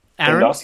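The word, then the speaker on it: Tsindos: but it starts with a T. – Yeah, it's T-S-I-N-D-O-S.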